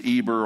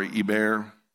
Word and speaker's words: Eber: He's very prominent in the verses of Shem's descendants, and his name is Eber or Eber, whichever one you prefer to pronounce it like.